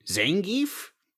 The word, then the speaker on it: Zangief: I've never been able to pronounce this guy's name. Zangief? – I think you got it.